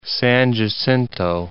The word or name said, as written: San Jacinto